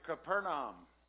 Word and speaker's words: Capernaum: When he left the wilderness, he went to Capernaum, is how it's pronounced.